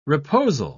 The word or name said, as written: reposal